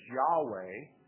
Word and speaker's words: Yahweh: Y-H-W-H, the tetragrammaton, which is pronounced sometimes as Yahweh, argued that that would be the correct pronunciation.